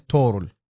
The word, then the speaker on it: طغرل: طغتكين، هي طبعا تنكتب في الكتب التاريخ طغتكين، زي طغرل هي تنطق تورل.